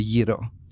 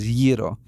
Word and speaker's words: gyro: So I think the universal Greek dish, especially here in the U.S., would probably have to be the gyro. And that's the proper pronunciation, is gyro, not gyro, not gyro, gyro.